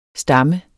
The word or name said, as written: stammen